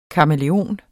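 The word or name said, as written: KAMÆLEONEN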